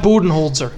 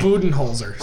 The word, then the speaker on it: Budenholzer: Yeah, Mike, it's Mike Budenholzer. – Mike Budenholzer? Is it Holzer or Holzer? Holzer. There's an L in there that John just was not pronouncing. – Budenholzer.